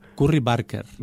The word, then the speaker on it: Barker: Todo el mundo lo pronuncia mal. Mira el apellido escrito. Curry Barker. – Barker. – Todo sí. el mundo se come la R sí. – y le llama Baker.